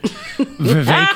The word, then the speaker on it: Vivek: Vivek rhymes with fake, a good way to remember it. Vivek Ramaswamy, who in a campaign stop on Friday in Iowa, referred to Congresswoman Ayanna Pressley as part of the modern KKK.